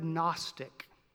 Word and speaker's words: Gnostic: It's the word Gnostic, with a silent G in the front.